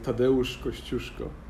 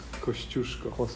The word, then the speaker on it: Kościuszko: Tadeusz Kościuszko. – Kościuszko.